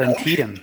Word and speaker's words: Antietam: I thought I nailed Antietam.